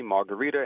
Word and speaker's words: margherita: My favorite is it's similar to a bruschetta, but it's the margherita, and you did pronounce it right.